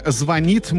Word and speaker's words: звонит: В ближайшем будущем ударение в слове «звонит» может измениться и переместиться на первый слог.